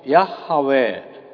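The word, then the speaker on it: Yahweh: I am what I am という言葉なんです。英語で言えばこれはヤッハウェ、まあ、エホバというふうにもう昔は発音されていましたが、ヤッハウェという名前のもとになっている言葉だと思います。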